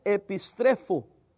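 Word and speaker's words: epistrefo (ἐπιστρέφω): Y la palabra usada es epistrefo, pronunciado en español, ¿verdad?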